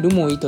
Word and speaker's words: 留萌: で、ルモイと読みます。